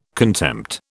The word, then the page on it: contempt: British English